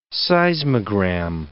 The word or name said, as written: seismogram